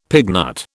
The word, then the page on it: pignut: British English